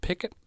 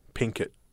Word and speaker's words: Pinkett: Is it Pickett? – Pinkett how it's spelt.